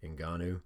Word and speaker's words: Ngannou: I don't know if that N is silent, but Ngannou and Andrei Arlovsky.